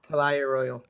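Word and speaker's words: Palaye Royale: Do you know what that band is? P-A-L-A-Y-E, Royale. – Oh, Palaya Royal.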